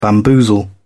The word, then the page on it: bamboozle: British English